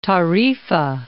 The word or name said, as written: Tarifa, Cape